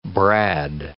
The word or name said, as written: brad